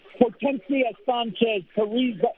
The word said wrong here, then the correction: Carrizales: No, excuse me. Hortensia Sanchez Carrizales.